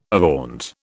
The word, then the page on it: avaunt: British English